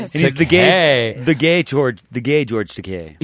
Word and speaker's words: Takei: Oh, excuse me, George Takei, because it rhymes with gay. Right. Even though I grew up with it was Takei.